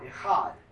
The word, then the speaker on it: echad: The word one is the Hebrew word echad. And it's kind of like you're clearing your throat, echad. That's how you say it.